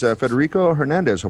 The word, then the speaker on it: Federico Hernandez: And also with us is Federico Hernandez.